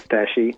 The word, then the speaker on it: agastache: So a lot of people listening might not know or haven't met any of the family of the agastache or the agastache, depending on, I don't know what the right pronunciation is. – Agastache.